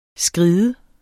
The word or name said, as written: skred